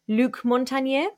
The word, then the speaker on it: Luc Montagnier: Speaking of conspiracies, we've also got this guy whose name I'm probably going to pronounce really badly, Luc Montagnier. – That's actually very nicely pronounced.